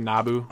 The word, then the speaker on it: Nabu: Nobu. – Well, let's look it up. – It's pronounced N-A... It's spelled N-A-B-U, I want to say Nabu. – Okay. Maybe Nabu. – Nabu, that's what it was.